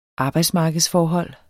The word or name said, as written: ARBEJDSMARKEDSFORHOLD